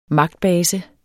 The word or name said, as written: MAGTBASE